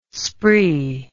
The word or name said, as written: spree